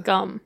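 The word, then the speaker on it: Skam: It's S-K-A-M. So Joanna says it rhymes with the English sum. – So scum. – Scum, I think, must be it.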